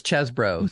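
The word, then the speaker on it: Chesebro: Chesbrough? – I believe it's Chesbrough.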